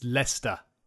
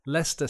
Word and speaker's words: Leicester: It's Leicester. Leicester City.